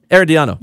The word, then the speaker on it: Herediano: How do you say it? – Herediano.